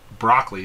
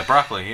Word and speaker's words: Broccoli: There are numerous James Bond references throughout the movie, resulting in a rumor that Albert R. Meraki, um, Broccoli is what I'm going to pronounce his name. – Oh yeah, Broccoli.